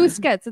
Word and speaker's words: Busquets: Basquiat. Basquets. Less. How do you say it? I always say it wrong. We're going to say Basquiat. Biscotti. They had Biscotti and Jordi Elbows, and they did fun. – Busquets. It's Busquets.